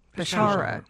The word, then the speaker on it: Peshawar: There was a terrible car bombing early today in the northwestern Pakistan city, Peshawar. Am I saying that right? – Peshawar.